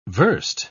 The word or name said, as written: verst